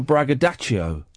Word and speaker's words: braggadocio: Brag is short for braggadocio. – Braggadocio. – Braggadocio. – Okay. – That's how it's spelt. That's how it's said.